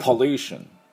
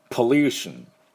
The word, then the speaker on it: Pollution: で、ルーのところにアクセントがあります。ですからこれしっかりやっていきたいと思います。では私の後について発音いきましょう。 Pollution。 Pollution。